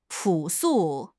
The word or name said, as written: 朴素